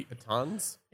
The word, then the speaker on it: piton: Pitons. – Pittons. yeah, we're idiots. I'm not pronouncing that word. – I believe the word is pronounced piton.